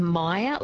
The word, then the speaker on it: Meyer: So maybe a Meyer lemon, so M-E-Y-E-R. – Yeah, that's it, yeah. Excuse my bad pronunciation of it.